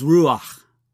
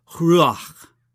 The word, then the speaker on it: ruach: That word for breath in Hebrew, if I can pronounce it even vaguely correctly, is ruach. And that word sort of imitates the very sound of breath, ruach, breathing in, breathing out.